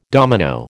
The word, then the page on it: domino: American English